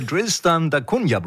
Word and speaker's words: Tristan da Cunha: The Tristan da Cunha Project? – Ja, ist perfekt ausgesprochen.